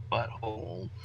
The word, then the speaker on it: But Whole: For those listening, butthole is spelled B U T W H O L E.